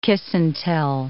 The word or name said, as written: kiss-and-tell